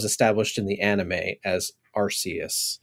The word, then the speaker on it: Arceus: And as I understand it, it is actually pronounced Arceus, because I have heard a lot of different pronunciations this year.